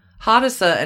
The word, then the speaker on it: Hadassah: Hadassah? I hope I'm saying that right. H-A-D-A-S-S-A-H? – So, yeah, Hadassah.